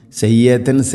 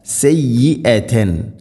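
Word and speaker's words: سيئة: സയ്യിഅ യാ ഹംസ ഉച്ചാരണം ശ്രദ്ധിക്കണം. സയ്യത്തൻ സയ്യത്തൻ എന്നല്ല സയ്യിഅതൻ.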